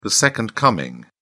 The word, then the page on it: second coming: British English